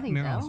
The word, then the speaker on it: Merrell: Merrell's, am I saying it right? – Yeah, Merrell's, so.